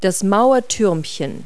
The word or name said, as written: turret